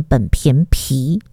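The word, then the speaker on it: 苯并芘: 但是我后来查了，是学名就应该怎么说，应该念苯平芘。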